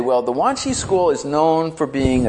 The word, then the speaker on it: Wanshi: And in Hongzhi is the Chinese pronunciation, Wanshi is the Japanese pronunciation of Hongzhi's name, and it became known as the Wanshi school in Japan.